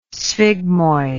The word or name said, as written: sphygmoid